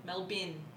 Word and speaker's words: Melbourne: No, Don't correct me. Melbin. Bin. B I N. Melbin. – But it's not spelt that way. – No, it's not, but that's how it's pronounced.